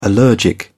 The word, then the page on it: allergic: British English